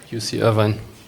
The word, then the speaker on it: Irvine: UC Irvine.